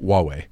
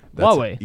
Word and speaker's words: Huawei: How do you say it? – Huawei. – Huawei. – Yeah. That's the closest English, pronunciation. It's not perfect, but Huawei is the kind of how that's actually supposed to be pronounced.